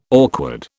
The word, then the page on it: awkward: British English